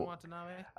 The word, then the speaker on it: Watanabe: Yeah, and then that's when how you say his name, Watanabe, whatever. – What? – I don't know how to say his name. – Watanabe, Ken Watanabe.